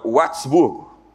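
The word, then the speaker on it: Würzburg: Eu sei que Watzburgo, é Watzburgo na pronúncia. O W tem som de V.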